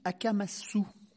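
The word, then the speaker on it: Akamasoa: Alors, ça s'écrit Akamassoa, mais ça se prononce Akamassou.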